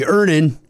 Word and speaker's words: Earnin: Earnin. – Earnin. – E-A-R-N-I-N is pronounced Earnin. I don't know how else you would pronounce it, but they really want to be clear that that's how to pronounce it.